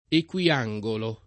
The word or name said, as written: equiangolo